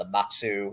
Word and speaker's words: Matsu: That was the team, the Matsu. Am I saying that correctly? – Yeah, Matsu Miners. Yep.